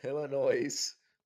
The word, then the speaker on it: Illinois: Illinois? It has an S. – You don't pronounce the S. – Do you not pronounce the S? – No. – Like, at all. Like, it's a no. Silent S.